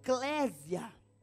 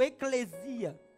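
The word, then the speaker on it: eclésia (ἐκκλησία): E essa palavra, no seu original grego, ela se pronuncia ek eclésia, ou eclesia, que se eu divido ela e eu vou no verbo dela, ela se pronuncia eclém, que significa chamados para fora.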